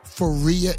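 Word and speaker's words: Fahrije: Faria is here. That's like spelled like F-A-H-R-I-J-E. I love it.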